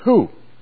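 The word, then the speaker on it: who (οὗ): The Greek word there for where is who, not W-H-O, but it's pronounced that way.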